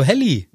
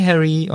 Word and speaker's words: Harry: Ah, hallo Helly. Ja, hier gar nicht. Hier gar nicht hallo Helly. Hier gar nicht so komisch ausgesprochen, sondern sie sagt einfach nur Hi Harry und äh, sie grinsen sich bisschen süß an.